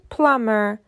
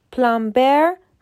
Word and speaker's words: plumber: لغت بعدی plumber به معنی لولکش تلفظ صحیح پلامر پلامبر غلطه توجه کنید که در این لغت B silent و خونده نمیشه در قسمت بعدی تلفظ صحیح چند تا شغل دیگر رو بهتون میگم.